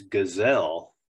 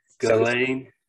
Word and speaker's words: Ghislaine: the name's it's a tough name to say, and I you know I mess it up all the time, but the S is silent when you say it in this kind of French Belgium uh, posh way to say it. Ghislaine. So Ghislaine.